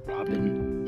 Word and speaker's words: Robban: Once again, this is Dr. Robot Siegel. And I believe she pronounces it Robin. It's spelled R O B B A N.